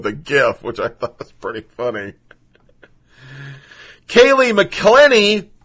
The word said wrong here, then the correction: McEnany: I'm sorry, let's say it right. Kaylee McEnany, I think that's how it is, little blonde girl that's on CNN all the time.